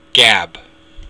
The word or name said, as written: geab